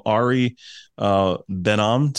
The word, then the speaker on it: Ari Ben Am: So I want to welcome Ari Ben Am to the program.